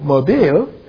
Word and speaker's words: Mobile: Doesn't know there's a place called Mobile. Because it's spelled the same way, but they call it mobile.